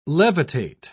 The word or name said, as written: levitate